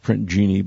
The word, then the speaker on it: Jinni: It's pronounced Print Genie, but it's spelled oddly.